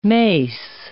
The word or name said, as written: mace